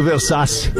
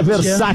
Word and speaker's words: Versace: Cara, tá me incomodando um pouco essa tua pronúncia do Versace. Eu acho que é Versace.